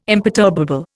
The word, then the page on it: imperturbable: Indian